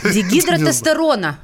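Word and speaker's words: дигидротестостерона: Это производное дигидростерона. Диг... Я правильно сказала? Наверное. А, дигидротестерона. – Один слог упустила.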